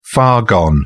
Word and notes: far gone: British English

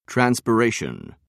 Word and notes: transpiration: British English

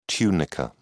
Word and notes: tunica: British English

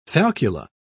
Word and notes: falcula: British English

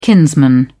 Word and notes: kinsman: British English

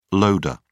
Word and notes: loader: British English

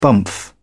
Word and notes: bumph: British English

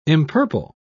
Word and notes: empurple: British English

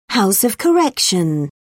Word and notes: house of correction: British English

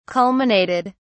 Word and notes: culminated: British English

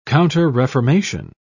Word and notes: counterreformation: British English

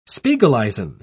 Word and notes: spiegeleisen: British English

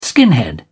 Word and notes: skinhead: British English

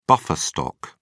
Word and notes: buffer stock: British English

